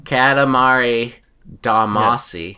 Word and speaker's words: Katamari Damacy: I think it's pronounced Katamari Damacy. Yes.